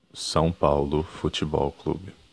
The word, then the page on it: São Paulo Futebol Clube: ptbr